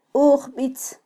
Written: ujbitz

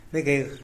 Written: begeh

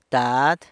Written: dad

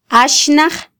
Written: axnaj

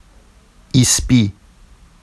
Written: isbi

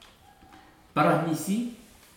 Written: paramisi

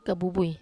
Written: kabubui

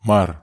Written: mar

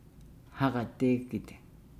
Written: hagadeegide

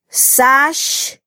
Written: sax

